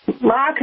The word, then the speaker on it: marque: Mark is spelled M-A-R-Q-U-E. So letter as in letter that you would write to somebody of M-A-R-Q-U-E.